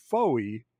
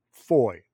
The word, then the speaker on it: Fowey: And F-O-W-E-Y, which I pronounced as Foey, appears to be, not appears to be, I am told is pronounced more like Foy.